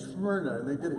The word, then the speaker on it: Smyrna: And we learned it was called Smyrna. You got to say it like that, all right? It's the only way to say it.